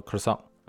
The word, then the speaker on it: croissant: 但其实它也不是一个英文单词，它在英文中的这个发音呢叫做 croissant，哎，这个拼写起来叫做 c r o i s s a n t，哎，可能很多人也都知道啊，这个词呢是来自于法语。